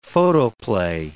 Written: photoplay

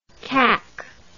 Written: kack

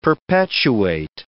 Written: perpetuate

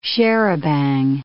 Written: charabanc

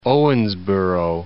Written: Owensboro

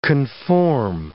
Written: conform

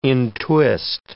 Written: entwist

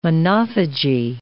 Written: monophagy

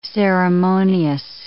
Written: ceremonious

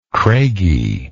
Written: craigie

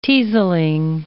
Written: teaselling

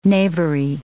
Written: knavery

